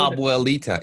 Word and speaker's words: Abuelita: Abuelita.